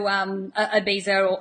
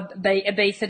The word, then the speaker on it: Ibiza: Now, Jinny and I actually met in Ibiza, in Spain. Now, for those of you who don't know Ibiza or Ibiza, depending on how you pronounce it, it's an island off the coast of Spain.